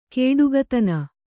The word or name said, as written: ಕೊಡುಗೈತನ